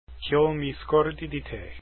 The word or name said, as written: Ch'io mi scordi